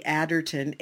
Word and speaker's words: Aderton: My name is spelled Sally Aderton, A-D-E-R-T-O-N.